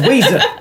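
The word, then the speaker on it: Ouiser: It's Weeza. And I like that her name is Weeza, but it's spelled O-U-I, like the French oui.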